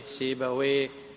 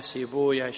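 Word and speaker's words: سيبويه: قلنا كل اسم ينتهي بواو وياء وهاء فاللغويون يقولون ويه، إسحاق بن راها ويه، نفط ويه، سيب ويه، شب ويه. أما المحدثون فيقولون لا، يكره نطق الاسم بويه. وذكروا أن ويه وصف النوصاف أو اسم من أسماء إبليس بالفارسية، وإنما يقال راهويا سيبويا شبويا.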